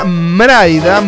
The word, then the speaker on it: Mraida: Se escribe M-R-A-I-D-A, Mraida, Mraida.